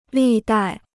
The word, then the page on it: 历代: lì dài